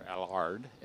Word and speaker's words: Allard: And then the Allard, or Allard, as they pronounce it overseas, the Allard has also packed up, and I'm not sure what happened to him.